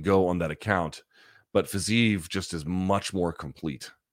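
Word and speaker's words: Fiziev: I favor Fazeev. That's how it was told to me, how it's pronounced, by the way.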